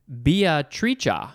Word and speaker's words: Beatrice: There is another pronunciation. Beatrice. That is another way to pronounce the name Beatrice, because this is an Italian word.